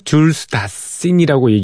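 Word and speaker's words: Jules Dassin: 어, 줄스 다생이라고 어, 그 현지 발음은 줄스 다신이라고 얘기를 하는 모양이에요.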